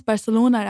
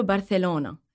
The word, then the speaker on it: Barcelona: أول شيء, did I pronounce Barcelona right? – Yes, you did. هلا, Spanish بيقولوا Barcelona. – Yeah. – But you can say Barcelona.